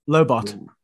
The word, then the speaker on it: Lobot: Lobo, is that how you pronounce it? – Lobot. – Lobot. Mm. Yes. – Short for lobotomy.